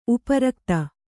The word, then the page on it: ಉಪರಕ್ತ: uparakta